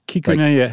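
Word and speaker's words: Kikunae: So here's a gentleman that comes along in 1909, and his name is Kakune Akita. Is that right? – Not bad. – What'd I get wrong? – There's like an extra little half syllable in there. Kikunaya.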